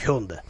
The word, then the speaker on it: Hyundai: А, Объявлены цены на Hyundai, Hyundai Santa Fe, правильно говорить, Hyundai, и Kia Ceed, правильно говорить, Kia Ceed, я правильно говорю.